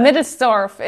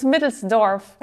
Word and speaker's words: Middelsdorf: Middelsdorf. It's a German name, so don't worry. I'm the only one, so it's Middelsdorf.